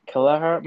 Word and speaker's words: Kelleher: It's Kelleher, about as Irish as you can possibly get.